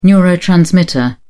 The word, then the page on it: neurotransmitter: British English